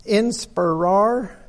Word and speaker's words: inspirare: Looking at the word inspire, this word inspire comes from the Latin word inspirare. I'll spell it. Maybe you can pronounce it different. I-N-S-P-I-R-A-R-E. Inspirare, which literally means to breathe into.